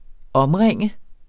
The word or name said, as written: omringe